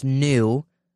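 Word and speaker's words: nu: So they skipped the N-U, which is pronounced new, letter of the Greek alphabet for the COVID variant.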